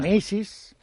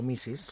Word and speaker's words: Mrs: Missis, MRS, pronunciado Missis, claro.